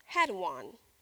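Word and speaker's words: hedone: The Greek word is hedon, but it's spelled H-E-D-O-N, and it's where we get the English word hedonism.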